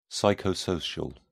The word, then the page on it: psychosocial: British English